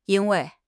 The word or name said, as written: yin1 wei4